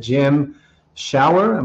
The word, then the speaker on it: Jim Schauer: To help us do just that, we have here with us today, Jim Schauer.